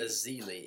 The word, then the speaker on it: Ezeli: And then they drafted the Festus Elijah in the very last pick of the first round. – Festus Ezeli. – Ezeli, yeah. I'm sorry I butchered that, but, you know, none of you knew who he was either, so I don't feel sorry.